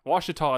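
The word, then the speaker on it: Washitaw: The Ouachita Nation claims, and I have to stop and point out, Ouachita in this case is spelled W-A-S-H, as in to wash something, I-T-A-W, Itaw. So there you go.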